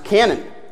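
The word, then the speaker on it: canon (κανών): Now the Greek word translated as rule literally is pronounced canon.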